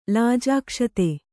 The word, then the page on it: ಲಾಜಾಕ್ಷತೆ: Kannada